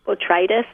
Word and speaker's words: botrytis: So I got online and was horrified to see that it looks like I have what they're calling botrytis. Is that how you say it? – Yes.